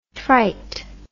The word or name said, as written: trit